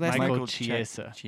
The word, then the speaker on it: Michael Chiesa: Michael Chiesa.